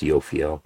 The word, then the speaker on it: Jophiel: He says, I conjured the Jupiterian planetary intelligent Yophiel, spelled Jophiel, but pronounced Yophiel, who arrived immediately at the end of his conjuration.